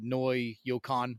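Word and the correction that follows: Koi no Yokan (恋の予感): one. – Koi no Yokan.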